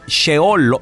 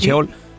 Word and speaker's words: Sheol: Pero que si te portas mal, si tus actos fueron malos, te llevan a un lugar que se llama el Sheol. ¿Lo dije bien, Juan? – Es correcto. – El Sheol.